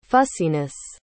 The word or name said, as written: fussiness